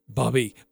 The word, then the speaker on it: Bubba: And Ajay and I had a chance to catch up with Bubby. There I go again. Bubba Schweigert. He is the head coach of the North Dakota Fighting Hawks. I actually mispronounced and called him Bubby when we introduced him.